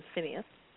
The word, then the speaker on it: Phineas: Okay, well, let's look at Pinhas's, or as you said, the other pronunciation is Phineas.